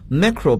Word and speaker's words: macro: 那我想说的是，和它相对应的前缀是 macro，要读作 macro，macro。 macro 表示微观的、宏大的，所以在英语中，macrocosm 就是指宏观的宇宙或者是广义宏观。